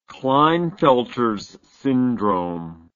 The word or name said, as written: klinefelters syndrome